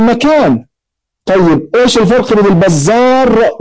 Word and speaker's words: البزار: مثلا البزار بالراء المهملة والبزاز بالزاي المعجمة، البزار والبزاز هذه نسبة، هو ما اتفقت صورته في الخط اهو بزار وبزاز الاثنين متفقين في الخط ولكن اختلفت في النطق.